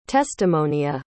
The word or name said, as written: testimonia